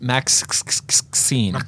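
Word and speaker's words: MaXXXine: Pronounced Maxine. – Maxine spelled with three X's.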